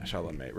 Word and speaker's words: Chalamet: I don't know how you say it. Chalamet, right? – Chalamadingo. – Yeah. – It's. I think it's Chalamet. – Yeah, Chalamet.